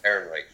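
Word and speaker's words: Ehrenreich: Aaron Reich, yeah.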